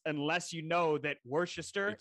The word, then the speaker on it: Worcester: Yeah, but the Worcester in Ohio is actually spelled the way Worcester is pronounced.